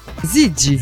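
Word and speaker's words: Ziggy: Abbiamo appena ascoltato Gigi Marley. – No, attenzione, no, Gigi. – Zigi. – Zigi. – Zigi. Perché io ho la pronuncia della Z, che è un po' particolare.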